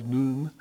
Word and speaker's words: nun: And we're now to the word N-U-N, nun, nun. Well, I've heard it pronounced by those Hebrew scholars, noon, so we'll just go with that.